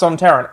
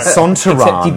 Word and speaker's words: Sontaran: I think it's pronounced Sontaran. I'm from the bloody place. – That's right, Sontaran, the director wanted it.